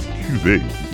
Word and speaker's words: cuvée: or as the French say, un jeu de mots, as when it is pronounced in French as cuvée, it means a specific blend or batch.